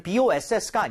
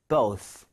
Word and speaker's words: both: 이게 상사를 얘기하는 boss가 아니죠. 그러니까 boss라고 하시면 안 돼요. both 해서 윗니 아랫니 사이에 혀가 살짝 나와야 됩니다.